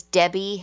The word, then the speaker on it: Debby: My full name is Debbie Handrich, and Debbie is spelled D-E-B-B-Y. And Handrich is just like it sounds. It's H-A-N-D-R-I-C-H.